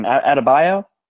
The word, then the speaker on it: Adebayo: I think it was Bam how do you say his last name? Adebayo? – Yep, that's right.